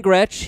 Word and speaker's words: Gretsch: I knew Gretsch rhymed with catch, obviously, so it's kind of...